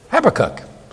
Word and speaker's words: Habakkuk: Now, there are several ways to pronounce that name. Habakkuk. Some pronounce it, what? Habakkuk. So where you put the accent.